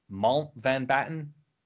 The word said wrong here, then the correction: Mountbatten: Or am I putting too many syllables in there? – Dickie. Richard Mountbatten.